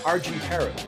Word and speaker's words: Argintaru: Tonight, we welcome fellow wrestling photographer and Gemini Award-winning producer, Steve Arginataru. I got it right, didn't I? – You did.